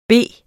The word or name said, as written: bede